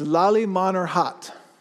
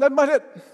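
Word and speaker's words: Lalmonirhat: That's a mouthful right there, but they don't say it like that, right? You go, where are we going? Lemmanhet. What? What did you just say? Yeah, Lemmanhet. Okay, we're going to Lemmanhet.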